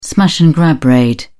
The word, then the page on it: smash and grab raid: British English